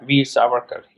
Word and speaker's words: Savarkar: I want to say Veer Savarkar. – Yeah, Veer Savarkar.